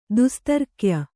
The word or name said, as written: ದುಸ್ತರ್ಕ್ಯ